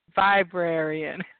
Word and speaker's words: Viberarian: It's just like librarian, only Viberarian.